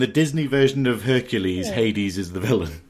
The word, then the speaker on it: Hades: Hades. Rhymes with Hercules.